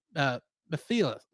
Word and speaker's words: Mephiles: And in the English dub of Shin Ultraman, he played alien Mephiles. How do you say his name? – Mephiles? – Yeah, basically like Mephistopheles.